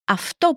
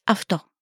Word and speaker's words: αυτό: So we have αυτό παρακαλώ. Let's take a look at the word for this. In Greek, this is αυτό.